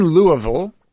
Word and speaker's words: Louisville: And you're currently in Louisville, as we say. – Great pronunciation.